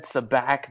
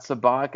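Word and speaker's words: Sabacc: Yeah, where we had to say that Han said Sabac, not Sabak, and then you kind of just to play it off, you said Han and not Han.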